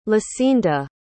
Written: lisinda

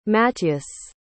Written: matyas

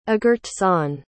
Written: agurtzane

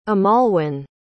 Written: amalwin

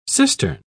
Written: cistern

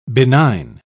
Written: benign

